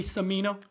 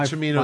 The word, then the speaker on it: Cimino: It's actually Cimino. – Cimino, sorry. John Cimino. – Michael Cimino is the director. – Okay, my fault. – Yeah, the director. – It is. – The correct pronunciation is Cimino.